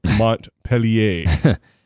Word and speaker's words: Montpellier: And so they go to the middle of nowhere, France, not Vermont, Montpellier. By the way, that is correct pronunciation. I'm taking French lessons.